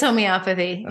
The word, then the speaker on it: homeopathy: Am I saying it right? Homeopathy? – It's homeopathy.